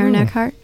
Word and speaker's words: Eckhart: With Aaron Eckhart. Eckhart.